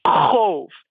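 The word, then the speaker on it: qov: Cove. I say it rhymes with stove, except the first sound is like you're choking on spinach.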